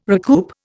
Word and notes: recoup: Indian

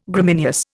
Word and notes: Gramineous: Indian

